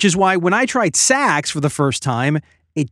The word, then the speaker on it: Saxx: I recently discovered this brand of underwear called Saks. That's spelled S-A-X-X.